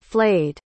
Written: flayed

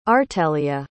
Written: artelia

